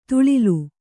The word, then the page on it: ತುಳಿಲು: tuḷilu